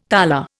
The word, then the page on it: tala: Indian